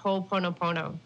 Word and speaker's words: Ho'oponopono: Next up, we're going to be talking about Ho'oponopono.